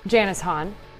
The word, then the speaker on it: Janice Hahn: Janice Hahn, supervisor.